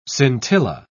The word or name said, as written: scintilla